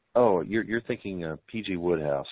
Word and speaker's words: Wodehouse: His name is spelled W-O-D-E-H-O-U-S-E, but it's pronounced Woodhouse.